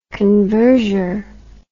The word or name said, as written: converger